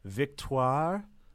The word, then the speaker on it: Victoire: Uh, no, the uh, daughter of Bill and Fleur, their oldest, is named Victoire Weasley. – That was a nice um, pronunciation.